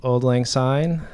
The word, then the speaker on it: Auld Lang Syne: This is our version of wrecking the song Auld Lang Syne.